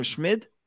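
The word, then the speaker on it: Schmid: Schmidt? Saying it right? Wow, saying it right.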